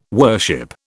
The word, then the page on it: worship: British English